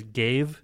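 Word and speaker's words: Ghave: Gave is already a word. – Yeah, and you would spell it like G-A-V-E. Why would you spell it differently if it's going to be pronounced the exact same?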